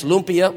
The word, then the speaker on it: lumpia: And then it's Tagalog, the language, and it's lumpia, what you eat.